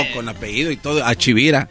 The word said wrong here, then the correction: Hashibira: Hashibira, ah, Hashibira. Tú, eh, cara de de marrona flaca.